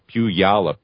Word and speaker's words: Puyallup: Puyallup is how they pronounce it there in Washington, southeast of Tacoma.